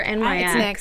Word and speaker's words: NYX: NYX lip color. Speaking of lips on fleek. – Oh, yeah. – I love this. Am I saying it right, girls? NYX or NYX? – It's NYX.